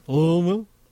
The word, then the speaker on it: Louisville: I went to Nashville and Louisville. – Louisville? – Oh, a 502. – It's old. The proper way to say Louisville is Louisville.